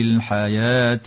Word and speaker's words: الحياة: الحياة الدنيا. واتفقت المصاحف على رسم الألف واوا في كلمة الحياة مع وجوب النطق بالألف لا بالواو، وتدغم لام التعريف في كلمة الدنيا نظرا لوقوعها قبل الدال، والنون الساكنة في كلمة الدنيا حكمها الإظهار المطلق.